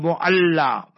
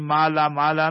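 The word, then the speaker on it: Mu'alla (معلاة): it's called Mu'alla. Our people mispronounce it, or they pronounce it incorrectly, Mala Mala, not Mala Mala. It is no Mala Mala and no Pala Pala. It is Mu'alla.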